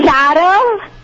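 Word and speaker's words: Shaarav: His name is Saurabh. – How do you spell it? – S-H-A-A-R-A-V, V as in Victor. – Saurabh.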